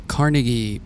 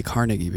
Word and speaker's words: Carnegie: That's, I know as a New York person, you say Carnegie because of Carnegie Hall, but we Carnegians. – Yes. – We say Carnegie. – I know. And I was really good about the pronunciation when I was in situ and I met with the teen council and I was like, look, I say Carnegie because we have Carnegie Hall here, but I understand that it's Carnegie.